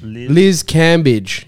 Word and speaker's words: Cambage: Liz Cambridge? – Liz Cambage. Yeah. – Cambage? Or Bridge? – Cambage. – Cambage. Oh, Cambage.